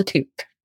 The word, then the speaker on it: toque: Never have enough, but it's called a toque. – A toque? – A toque. – Like full of a toque? – Toque. It's spelled T-O-Q-U-E.